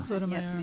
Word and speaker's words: Sotomayor: Regarding Sotomayor, do I say her name right? Sotomayor? – Sotomayor. Yep.